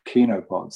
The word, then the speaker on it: chenopods: looks like it should be pronounced chenopods, but it's actually pronounced chenopods, C-H-E-N-O, pods.